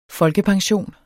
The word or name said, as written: folkepension